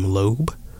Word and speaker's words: Loeb: But yeah, coming up we have The Music Inside by Chuck Loeb. I hope I'm pronouncing that right. Chuck Loeb. I can spell it. Spell L-O-E-B, that last name. Loeb.